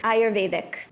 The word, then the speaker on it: Ayurvedic: First, how do you pronounce Ayurvedic? – Ayurvedic. – That's right.